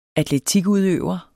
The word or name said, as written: atletikudøver